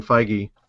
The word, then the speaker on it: Feige: But they had, you know, Kevin Feige came out, and apparently that is the way to say his name, Feige.